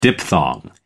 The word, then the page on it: diphthong: American English